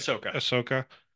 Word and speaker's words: Ahsoka: August is Ahsoka. Did I say that right? – Ahsoka.